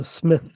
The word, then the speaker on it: Psmith: He goes to his room and runs into Smith there. Smith explains that he spells his name with a silent P.